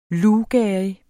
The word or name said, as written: luvgerrig